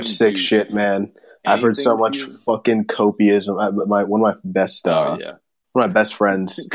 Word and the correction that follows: copium: Copium. – It's actually, I said it wrong. It's copium. My friend Owen.